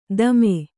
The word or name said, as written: ದಮೆ